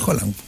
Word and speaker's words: Haaland: Ayer aprendí cómo se pronuncia. – Se pronuncia Haaland.